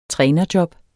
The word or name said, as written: trænerjob